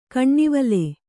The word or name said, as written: ಕೆನೆವಾಲ್